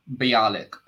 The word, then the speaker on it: Bialik: So Amy Farrah Fowler, I can't pronounce her second name, Mayim Bialik. – Yeah. Oh, Bialik, yeah. – Bialik.